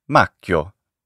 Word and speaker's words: Macchio: How would you pronounce his name? – I remember it's M-A-C-C-H-I-O, right? – Yeah, yeah. – Macchio.